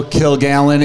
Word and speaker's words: Kilgallen: On Twitter, it's simply at Joe Kilgallen. It's spelled K-I-L and then the word Gallen.